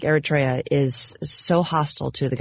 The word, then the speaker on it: Eritrea: And I have to think of this little place in Africa called Eritrea. Am I saying that right? – Yeah, yeah.